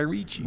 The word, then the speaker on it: Ritchie: I don't know how to say his fucking name. It's Ritchie. – Guy Ritchie. – Guy Ritchie. – Ritchie. – Guy Ritchie. – Like Richie Rich.